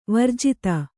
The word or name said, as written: ವಿರಾಜಿತೆ